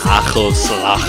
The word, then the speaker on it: Hagelslag: Hey Chanto, I think you mean Hagelslag. Hagelslag. You gotta pronounce the G with